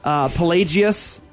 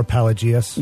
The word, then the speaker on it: Pelagius: Pelagius. – Or Pelagius.